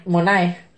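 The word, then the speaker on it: Monáe: Janelle Moané. – Eh, Monae. – Monae Ah,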